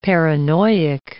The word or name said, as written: paranoic